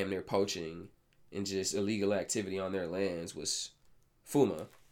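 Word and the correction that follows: FUNAI: I did say that correctly, didn't I? No, FUNA. But yes, FUNA.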